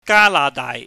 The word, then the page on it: ganahdaaih: Cantonese